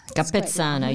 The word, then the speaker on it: Capezzana: Capezzana, your Italian yes. pronunciation is excellent.